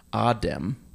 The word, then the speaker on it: Adam: But then the mystery of what happened to Lady Justice's son, and his, you know, his name is Adam, but you don't pronounce it Adam. You pronounce it like the old Atlantean way, which is Adem.